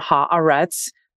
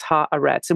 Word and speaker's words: Haaretz: the correct pronunciation of haaretz is and i said it this time haaretz um, pause between for those of you that are also learning this word, which is the newspaper in Israel pause between the first syllable and the second, no pause between the second and the third. So it's ha arets.